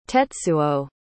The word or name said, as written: tetsuo